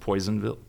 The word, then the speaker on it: Personville: It's a town called Personville, but pronounced Poisonville.